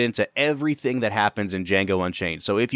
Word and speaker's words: Django: The movie Django Unchained is about a young slave named Django, the D is silent, and his partner in crime, a German bounty hunter named Dr. King Schultz.